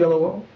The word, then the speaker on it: Oyelowo: Did I say that right? – Yes. – David Oyelowo.